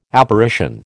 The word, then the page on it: Apparition: American English